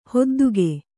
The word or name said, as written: ಹೊದ್ದಿಕೆ